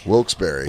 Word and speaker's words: Wilkes-Barre: Wilkes, how do you say this? Wilkes Bar? – I don't know. – Wilkes-Barre? – I'm from there. It's Wilkes-Barre. – Wilkes-Barre.